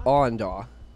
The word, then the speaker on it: Awendaw: It's like Awandaw? – Awandaw. – How do you spell that? – A-W-E-Daw. – D-A-W? – A-W-E-N-D-A-W, yeah.